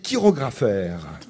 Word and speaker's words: chirographaire: Alors le terme qu'on emploie, c'est créancier chirographaire. Chirographaire, ça s'écrit C-H-I-R-O-G-R-A-P-H-A-I-R-E-S.